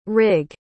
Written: rigg

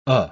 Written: uh

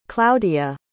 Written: Klaudia

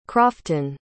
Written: crofton

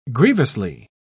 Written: grievously

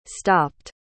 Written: stopped

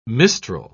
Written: mistral

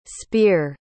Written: speer

